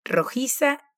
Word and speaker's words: rojiza: La R de inicio de palabra en español tiene una pronunciación vibrante, r, rojiza, igual que la palabra terroso, que tiene doble R en el medio. En ambos casos el sonido es vibrante.